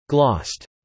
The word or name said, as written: glossed